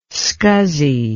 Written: SCSI

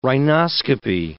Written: rhinoscopy

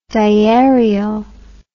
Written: diarial

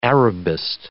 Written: Arabist

